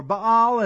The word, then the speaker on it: Baal: He went and served Baal, or Baal as it technically is pronounced, and worshipped him.